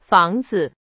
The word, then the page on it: 房子: Chinese